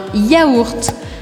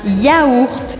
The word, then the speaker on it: yaourt: Então em francês a gente não fala iogurte, yogurt, nada disso. A gente fala yaourt. Yaourt. Pense em engolir se você quiser pronunciar o G, porque não tem G nessa palavra.